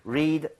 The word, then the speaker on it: read: 발음만 read, read, read가 되겠습니다. 이거 좀 주의하셔야죠.